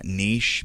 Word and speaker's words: niche: You have picked a niche, and you know for a long time, I tried to start pronouncing that niche because I think it's a French word and that's the way you're supposed to pronounce it, but I'm from the South, and I can't make myself do it. So I say niche.